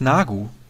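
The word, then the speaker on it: Neagu: Christina Nyagu heißt Nagu. Das E wird wohl nicht ausgesprochen, habe ich gelesen.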